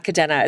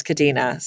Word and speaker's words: Kadena: So one, it's not Kadena, it's Kadena. So I mispronounced that entire episode.